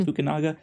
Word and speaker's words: Fukunaga: and I think it's still Kerry Joji Fukunaga. Is that how you say his name? Fukunaga.